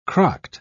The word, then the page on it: crocked: British English